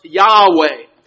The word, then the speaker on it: Yahweh: But most scholars today believe that it is pronounced Yahweh.